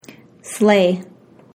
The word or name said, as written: slay